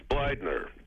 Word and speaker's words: Bleidner: It's Bleidner.